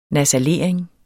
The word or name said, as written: nasalering